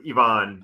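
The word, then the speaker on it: Iván: It's even, like hard even. E, so Ivan. – Hard E, okay, Ivan, okay. – So Ivan Rodriguez, Ivan, you know. – Ivan Herrera.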